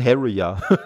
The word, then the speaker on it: Harrier: Also ich würde sie wie den Jet aussprechen und das wäre ein Harrier.